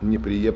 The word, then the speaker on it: Неприе: Произношение Неприе правильное, потому что я воевал на Калининском фронте, в этой деревне жил. Ручаюсь за ударение.